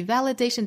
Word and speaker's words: validation: validation 注意 validation 这个词尤其注意除了重读的位置在 day 这个音节以外呢还要注意第一个音节这个 v 的发音那对应的字母 v 呢是上齿摩擦下唇的这个音发成 validation No amount of external validation can ever make you feel safe.